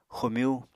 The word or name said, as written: xomyú